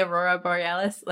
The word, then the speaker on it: Aurora Borealis: And the way that I did the color of the, I can't pronounce this, the Aurelis Borealis. What is it? – Aurora? – The Aurora. I knew I was going to mess that one up. The Aurora Borealis.